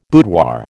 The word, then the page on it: boudoir: American English